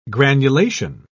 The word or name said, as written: granulation